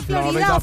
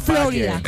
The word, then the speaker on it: Florida: Jefe, ¿se dice Florida o Florida? ¿Cómo se dice? – Si estás aquí, di Florida. Y si estás allá, di Florida.